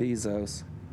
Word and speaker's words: Bezos: Bezos.